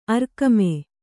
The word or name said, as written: ಅರ್ಕಮೆ